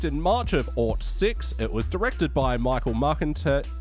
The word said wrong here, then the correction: Marcantel: Oh, there's an L there. It's Marcantel.